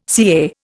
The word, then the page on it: CA: Indian